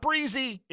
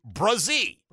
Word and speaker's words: Brazee: It's not Breezy. It's Brazee. – Brazee. – Okay? Brazee. In case he gets drafted here, Trevor's former teammate at Clemson, not how you would expect based on the way it's spelled, but definitely how it's pronounced.